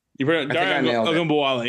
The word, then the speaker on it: Ogunbowale: Speaking of running backs in the AFC South, Dameon Pierce was officially placed on IR, meaning that Rex Burkhead and, I'm going to try to pronounce his name correctly, Dare Ogunbowale. – You pretty much nailed it. Ogunbowale. Yeah. You pretty much nailed it. – Ogunbowale. I think that sounds better.